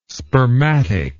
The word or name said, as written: spermatic